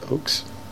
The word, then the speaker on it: Ochs: Ochs? – Uh, Ochs.